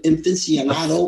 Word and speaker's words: aficionado: If you're an aficionado, one of these days I'm going to say that right. How do you say that? Aficionado. – Aficionado. – There you go.